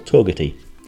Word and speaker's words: Togwotee: Before you get to turn left to go to Yellowstone, continue on, and you come to a place called Togati. That's actually my best pronunciation of it, because it looks like Togwotee, T-O-G-W-O-T-E-E.